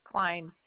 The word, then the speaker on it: Cline: Julie Klein. – One moment. One moment, I'm still trying to find you. And what did you say your last name? – Klein, C-L-I-N-E. – What is it, C-L? – C-L-I-N-E.